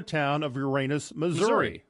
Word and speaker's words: Uranus: And yes, the town's name is pronounced just as any class clown would say it, Uranus. That's the town. – Yeah. – And like the planet name.